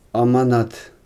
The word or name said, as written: amanat